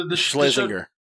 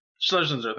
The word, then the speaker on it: Schlesinger: Eliza Schlesinger. – Schlesinger. – Schlesinger, thank you.